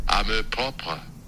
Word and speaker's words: amour propre: Amour propre.